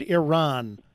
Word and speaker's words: Iran: By the way, I got to ask, I've always said Iran. Am I saying it wrong or am I saying it right? – That's perfect pronunciation, yes.